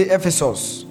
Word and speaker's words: Ephesus: Say Ephesus.